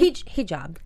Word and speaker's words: hijab: She wore a jihad? – I think that's how you pronounce it, yeah. – If I'm wrong. – Hijab. – Hijab. Sorry. That's what it is.